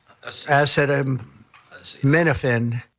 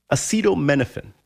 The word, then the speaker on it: acetaminophen: Acetaminophen. – Acetaminophen.